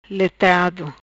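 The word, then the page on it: Letrado: patua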